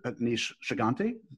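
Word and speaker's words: Aneesh Chaganty: I don't want to butcher his name, but Anish Shiganti. Did I have that close? – Yes.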